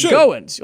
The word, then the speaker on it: Goins: It's Jacob Goins. Like, you're going somewhere, right?